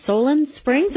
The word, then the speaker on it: Solon Springs: Jim's calling from Solon Springs.